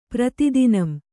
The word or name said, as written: ಪ್ರತಿದಿನಂ